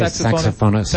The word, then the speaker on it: saxophonist: Sassophonist, is that how you say it? Sassophonist, I think that's right. – No, I say saxophonist.